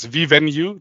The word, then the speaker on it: Vivenu: I said that right, yeah? – You said that absolutely right. So it's Vivenu. That's the perfect way to pronounce it.